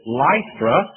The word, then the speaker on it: Lystra: "Then he came to Derbe," and in the south we pronounce L-Y-S-T-R-A, Lystra. But I was in the north not long ago and pronounced it Lystra, and a dear loving brother came by and said, "You southerners are ignorant.